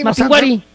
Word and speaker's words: Mapinguari: Ah, no, es que no tiene acento en la I. – No tiene acento en la I. – Mapinguari.